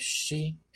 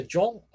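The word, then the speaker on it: yon (四): Es un número que suena en japonés shi, en algunos casos. Se pronuncia yon o shi.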